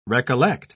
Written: recollect

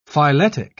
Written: phyletic

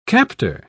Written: captor

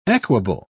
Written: equable

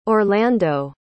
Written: orlando

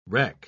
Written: rec

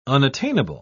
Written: unattainable

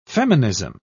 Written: feminism

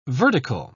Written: vertical

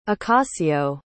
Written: acacio

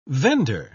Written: vendor

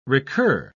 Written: recur

